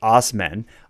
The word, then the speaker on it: Assman: When Assman, pronounced Osman, applied for a personalized license plate, his request was denied.